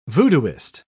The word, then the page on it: voodooist: British English